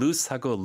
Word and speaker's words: loose: 가끔 loose하고 lose, 잃어버리다, 헷갈릴 수 있거든요. – 네. – 그러니까 loose, 헐렁헐렁한 거는 O가 두 개 있고 네. – S 발음이 S 발음이에요.